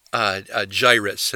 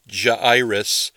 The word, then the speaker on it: Jairus: Before I get to the devotion, I want to explain that the man's name in this account is Jairus, and that's how I'll pronounce his name. I know that many people will say Jairus, and in fact, in Greek, there is an unusual punctuation mark called a diéresis on the third vowel that begins his name, Jairus, something like that.